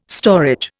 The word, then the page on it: storage: Indian